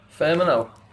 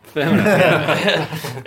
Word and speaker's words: Firmino: Firmino. – Firmino.